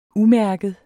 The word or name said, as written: umærket